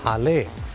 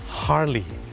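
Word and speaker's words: Harley: คนไทยออกเสียงว่าฮาร์เลย์แต่เจ้าของภาษานะครับไม่ได้ออกเสียงว่าฮาร์เลย์ครับเขาออกเสียงว่าฮาร์ลีฮาร์ลีฮาร์ลีเดวิดสัน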